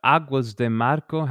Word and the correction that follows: Águas de Março: How do you say that? – Yeah. Águas de Março. – Março. Yeah, that's right.